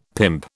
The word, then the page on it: pimp: British English